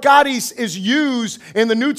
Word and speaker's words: charis: It is the word charis. Say charis. It is spelled C-H-A-R-I-S.